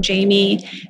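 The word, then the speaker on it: Jhaimy: You can always follow me on Jamie Fernandez on TikTok or on Instagram or on LinkedIn.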